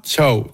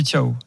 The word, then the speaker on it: Thiaw: Tiens, d'ailleurs, comment tu m'as prononcé Thiaw, le remplaçant de Kimmich, ce soir? – Ciao. – Ah, Thiaw, en allemand, on dit Thiaw.